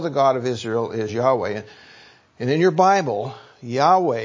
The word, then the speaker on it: Yahweh: And we pronounce it Yahweh. Some translations will pronounce it Jehovah.